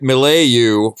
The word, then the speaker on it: milieu: I've only seen it written, and I've been pronouncing it milieu for 50 years.